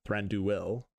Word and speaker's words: Thranduil: However, it's important to note that he is Thranduil's son, and Thranduil, I'm awful at pronouncing these names, Thranduil, potentially.